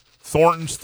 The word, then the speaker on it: Thornton's: Thornton's 32-ounce vitamin water.